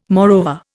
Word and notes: moreover: Indian

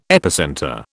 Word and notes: epicentre: British English

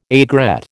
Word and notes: aigrette: American English

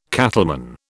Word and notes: cattleman: British English

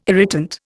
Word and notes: irritant: Indian

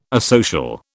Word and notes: asocial: British English

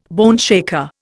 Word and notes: boneshaker: Indian

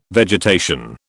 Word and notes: vegetation: British English

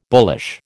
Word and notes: bullish: American English

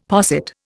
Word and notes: posset: Indian

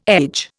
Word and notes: edge: Indian

